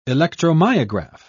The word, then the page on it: electromyograph: British English